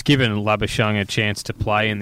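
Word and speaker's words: Labuschagne: But concussion symptoms can flare and linger, which would make him in doubt for that game, which would mean Lobashane, pronounced like champagne, might come into the side, meaning there'd be four debutants.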